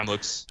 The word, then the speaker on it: Mamluks: I had to look this up, but you mentioned the Mamluks, or I can never say it right. But Mamluks.